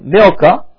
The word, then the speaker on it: Milcah: And it came to pass after these things that it was told Abraham, saying, behold, Milcah is pronounced M-I-L, slash or dash, K-A-H. C, there is a K sounding. Milcah.